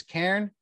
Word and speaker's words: Cairn: C-A-I-R-N. Can we? The pronunciation is Karen. It's not Karen. Like Karen's hassling the manager. It's not what we're saying.